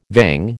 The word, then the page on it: vang: American English